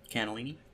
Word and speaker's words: cannellini: Cannellini.